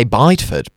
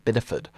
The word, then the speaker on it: Bideford: I say Bideford, but apparently it's not how it's pronounced. It's pronounced Biddeford.